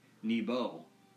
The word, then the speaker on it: Nebo: No, not Nemo. Nebo. – Nebo. – Or Neboo. I think that could also be another pronunciation for it. But N-E-B-O.